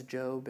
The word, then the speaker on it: Job: Maybe, you know, maybe you're like, Job, who, what? That was Job. It's okay. Most people pronounce it as Job.